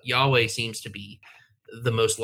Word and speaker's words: Yahweh: But it is interesting because a lot of the scholars – a lot of the Old Testament scholars who aren't Jewish will say, oh, no, it's Yahweh.